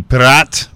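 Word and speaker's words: Piraat: I looked up how to pronounce it. I wasn't sure. It's P-I-R-A-A-T. Pirat.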